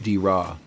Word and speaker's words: Draa: We think this is the River Dira, which is spelled D-R-A-A, but it's pronounced Dira.